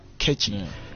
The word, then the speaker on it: KHETSHI: You gotta type it K H E T S H I Catchy. – Alright. – Yeah, said it right. Yeah, Catchy.